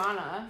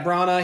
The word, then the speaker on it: Branagh: I heard different people say his name differently. – I've always heard Branagh. – Yeah, Branagh.